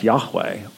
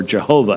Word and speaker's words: Yahweh: that's the English Bible translator's way of saying that's Yahweh, or Jehovah as we slaughter it in English. It could never have been pronounced Jehovah. Jehovah has too many syllables in it.